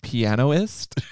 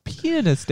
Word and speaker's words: pianist: Did you think it's pronounced pianoist? I am offended just for that. No, it's pianist, Daddy.